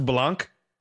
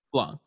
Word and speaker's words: Blanc: Is it Blanc. Pronounced Blanc? – Blanc, yes.